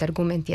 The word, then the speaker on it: argument: A ti akcenti poput argument i asistent nisu nikakvi novi akcenti, već su akcenti koji su doneti još pre dva veka u vreme Vuka Karadžića i Đure Daničića, kada je propisano da silazni akcenti mogu stajati samo na prvom slogu višesložnih reči i na jednosložnim rečima.